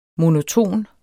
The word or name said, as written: monoton